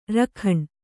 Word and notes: ರಖಂ: kannada

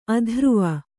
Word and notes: ಅಧ್ರುವ: adhruva

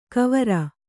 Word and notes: ಕುವರ: Kannada

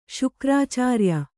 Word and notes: ಶುಕ್ರಾಚಾರ‍್ಯ: Kannada

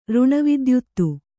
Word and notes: ಋಣವಿದ್ಯುತ್ತು: řṇavidyuttu

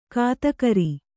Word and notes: ಗೊತ್ತುಗುರಿ: Kannada

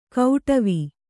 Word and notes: ಕೌಟವಿ: kauṭavi

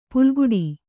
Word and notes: ಪುಲ್ಗುಡಿ: pulguḍi

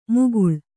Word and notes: ಮುಕುಳ: Kannada

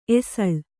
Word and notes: ಎಸಳ್: esaḷ

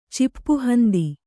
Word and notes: ಚಿಪ್ಪುಹಂದಿ: Kannada

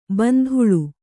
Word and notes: ಬಾಂದಳೆ: bāndaḷe

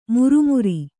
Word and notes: ಮಿರಿಮಿರಿ: miri miri

